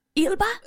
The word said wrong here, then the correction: Elba: You don't really know how to pronounce his surname, but he's your fella. – Idris Ilba? – Idris Elba, yeah.